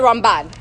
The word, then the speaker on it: Ramban: I said, the Rambam, the Ramban.